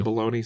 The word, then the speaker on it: bologna: Fried bologna sandwiches? Come on. – Bologna? Yeah, Silent G?